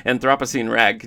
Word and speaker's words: Anthropocene Rag: Anthropocene Rag. Can you tell us a little bit about this one? – You didn't say it wrong. You said it right.